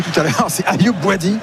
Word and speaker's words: Bouadi: Je me disais c'est qui Ayoubi, je regardais ma fiche, je me dis c'est ça, j'avais besoin d'un coup de brumisateur. – Il manquait une syllabe au milieu, quoi.